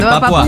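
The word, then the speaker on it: Papúa: Werner dijo nueva papuda. – Sí, lo dije. – No, nueva papuda. – No dije nueva... ¿cómo se dice? – Papua. Nueva papuda. – Nueva papuda. Dice papuda y yo dije papua.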